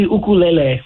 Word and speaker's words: ukulele: You said ukulele. Is that the proper pronunciation? Have we all been saying that word wrong for our entire life? – Well, there's two meaning, like uku. Yeah, it would be ukulele would be the correct way to say it.